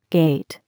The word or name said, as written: gate